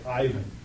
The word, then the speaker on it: Ivan: You and I would read it and say Ivan. But the Russian pronunciation is Ivan.